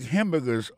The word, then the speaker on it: hamburger: N'a pas manqué que dans le mot hamburger, ça va comme d'abdi, il y a trois syllabes là-dedans. Quand on appuie et puis plus ça, c'est sous premier syllabe là.